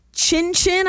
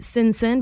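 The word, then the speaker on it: Cin Cin: Chin Chin, I believe is how you pronounce it. Right across the street, right? Some people are calling it Sin Sin because it's C-I-N-C-I-N.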